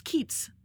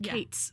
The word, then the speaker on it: Keats: Although, weirdly, Keats is spelled the same way, but it's pronounced Keats and not Cates.